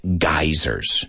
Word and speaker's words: geyser: So I posted something about geysers. Now, all of you know, what a geyser is, porque suena parecido en español, right? Geyser. You say geyser? Geyser. It's spelled G-E-Y-S-E-R, right? Geyser.